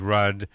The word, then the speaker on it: Rudd: And that's, you know, and people would always mispronounce my grandfather's names because it's R-U-D-D, Rudd, and they would say, oh, your grandfather's rude, isn't he? I said, no, he's sometimes abrupt, but never rude.